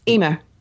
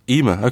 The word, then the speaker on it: Eimear: But a book called A Girl Is a Half-Formed Thing by, and I'm not sure how you pronounce this, but I think it's an Irish name, so you can perhaps help me out. Eimear McBride? – Eimear. – Eimear, okay, Eimear McBride.